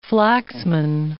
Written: Flaxman

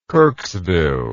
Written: kirksville

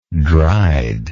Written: dried

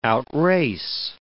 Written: outrace

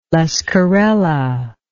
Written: lesquerella

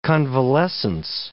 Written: convalescence

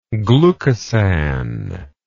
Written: glucosan

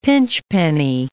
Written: pinchpenny

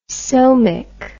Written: somic

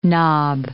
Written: knob

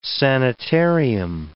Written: sanitarium

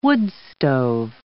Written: woodstove

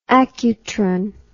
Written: accutron

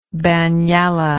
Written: banyalla

